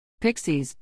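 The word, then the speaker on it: Pixyz: Pixies, pronounced Pixies, is the key that unlocks the industrial value of the Unity Industrial Collection.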